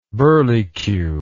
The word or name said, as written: burlecue